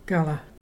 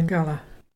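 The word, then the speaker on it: garla: Our local Mulgona word today is Gala. That's fire. And to spell that, there's G-A-R-L-A. Can you say Gala?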